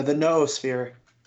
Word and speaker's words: noosphere: The noosphere. – The noosphere. – Yeah, I looked it up. It's noosphere. – Noosphere, or the noosphere, because it's three syllables.